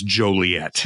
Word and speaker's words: Joliet: In fact, believe it or not, there is still it's a very antiquated, old, outdated ordinance in the city that prohibits the pronunciation of Joliet and says there's only one appropriate pronunciation, and that is Joliet.